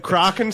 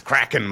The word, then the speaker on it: Kraken: Are you going to let Kraken slip through? – It's Kraken.